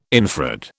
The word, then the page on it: infrared: British English